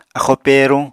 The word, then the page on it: axóo pèeruñ: apatani